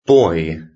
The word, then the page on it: boy: British English